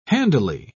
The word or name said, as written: handily